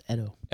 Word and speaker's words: Edo: It's actually pronounced Edo.